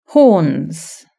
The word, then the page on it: horns: British English